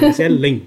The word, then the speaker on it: Grazielen: E por último, mas não menos importante, a nossa amiga aí, Graziele Fernandes. Eu pronunciei certo? Grazielen. É tem que ter um Ellen no final. – Ah, Grazielen.